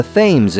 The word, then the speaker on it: Thames: It's the Thames in Connecticut, and was in England until they learned that Prince Albert, the husband of the super popular Queen Victoria, pronounced it Thames because he was from Germany. They often don't say their THs very hard.